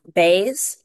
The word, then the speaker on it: Bays: Elizabeth Bays, last name spelled B-A-Y-S. – Thank you. It's pronounced Bays. – That's correct.